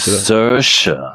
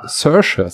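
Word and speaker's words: Saoirse: Sersha. – Sersha, so spricht man sie ja, aus.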